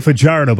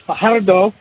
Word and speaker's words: Fajardo: Finally, in the chat with Cody Fajardo, Jamie again brought up how we all say Fajardo, but that's not actually how his name is pronounced. And TSN's Luke Wilson alluded to it, I think it was last game, and attempted to say it properly with the rolling of the R's.